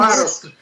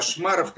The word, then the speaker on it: Шмаров: Кстати, ударение на последнем. Да, это мы, мы свя- свято чтим. – Шмаров, да, Шмаров, Кошмаров.